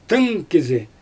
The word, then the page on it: tɯn kizi: Xyzyl